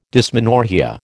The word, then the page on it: dysmenorrhoea: American English